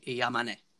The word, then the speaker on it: Yamane: And then supposedly this guy, I'm going to butcher his name right here, Kayohe Yamani? No, go ahead, Jake, take it. – Hold on, I'm looking, oh, Kyohei Yamane. – Yamane. – Kyohei.